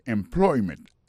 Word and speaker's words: employment: Alors, mot employment là, c'est employment. Accent tombé sous deuxième syllabe là.